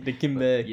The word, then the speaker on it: Dikembe: Dikembe.